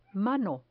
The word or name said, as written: mano